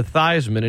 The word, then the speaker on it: Theismann: Your other loss was a guy named Joe Theismann, who decided he was going to change the pronunciation to Theismann in his campaign to try to win a Heisman.